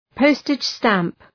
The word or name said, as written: postage stamp